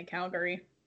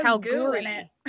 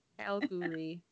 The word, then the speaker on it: Kalgoorlie: So please don't tell me you're trying to say Calgary. – No, it's spelled K-A-L-G-O-O-R-L-I-E. I guess it's Calgary, but it's Calgoorlie. Calgoorlie.